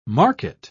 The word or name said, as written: market